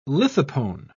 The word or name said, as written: lithopone